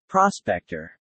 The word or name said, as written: prospector